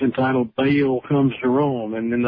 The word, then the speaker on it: Baal: Some people pronounce it properly, Baal, right back to some of the traditions we have with us today.